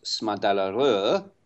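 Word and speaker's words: Smådalarö: So it's spelt Smadalaro. Now, I believe it's pronounced Smadalaro.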